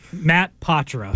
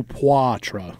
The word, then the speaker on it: Poitras: Matt Patra.